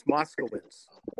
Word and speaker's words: Moscowitz: Let me correct you. It's Moscowitz.